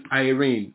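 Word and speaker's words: Irene (εἰρήνη): The Greek word for peace there is Strong's 1515 is irene, okay? Pronounced, this is spelled E-I-R-E-N-E, okay? But it's pronounced Irene.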